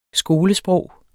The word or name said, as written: skolesprog